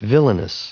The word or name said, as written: villainous